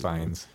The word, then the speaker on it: Fiennes: I don't know how to say his Fiennes.